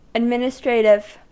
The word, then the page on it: administrative: American English